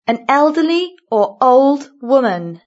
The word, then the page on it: an elderly or old woman: British English